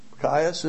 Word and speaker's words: qayis: So the pronunciation of the Hebrew word for summer fruit, Q-A-Y-I-S, Kais, is it? Is almost the same as Q-E-S, which is the word for end.